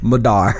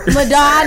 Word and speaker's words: Madar: Madar. – Madar, I knew it. Madar rhymes with radar, making a dream a reality.